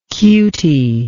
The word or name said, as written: cutie